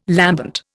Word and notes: lambent: Indian

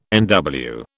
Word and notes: NW: British English

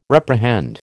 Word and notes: reprehend: American English

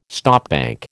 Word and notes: stopbank: American English

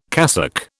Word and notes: cassock: British English

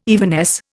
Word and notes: evanesce: Indian

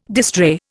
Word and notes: distrait: Indian

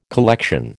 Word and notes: collection: American English

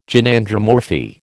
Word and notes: Gynandromorphy: American English

